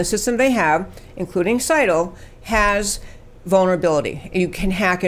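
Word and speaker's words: Scytl: According to a newspaper, the software vendor, how do you say S-C-T-Y-L? – Seidel.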